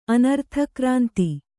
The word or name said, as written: ಅನರ್ಥಕ್ರಾಂತಿ